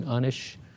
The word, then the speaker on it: Enosh: Now, the Hebrew is probably pronounced Enosh, and I've been pronouncing it that way, or even Unish, perhaps.